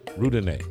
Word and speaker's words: Roudanez: Yeah, so how do you pronounce it? – R-O-U? – Yeah. D-A-N-E-Z. Charles. – Roudanez.